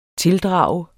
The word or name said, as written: tildrage